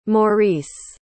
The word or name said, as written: Maurice